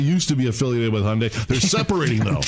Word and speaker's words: Hyundai: How do you say it? I'm sorry. – Hyundai, no. like Sunday. – Hyundai.